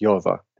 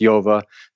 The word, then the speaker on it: Yehovah: It's Yehovah. That's how they say. If you ask someone in Israel how to pronounce that, it's Yehovah. The Pope says Hova. Right? That's why Jay-Z calls himself Hove. It's Jove, J-O-V-E.